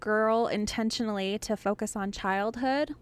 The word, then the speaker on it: Grrrl: So I know we're saying Riot Girl, so you probably think it's spelt regular, but it's actually spelt G-R-R-R-L.